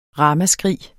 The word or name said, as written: ramaskrig